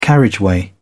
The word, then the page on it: carriageway: British English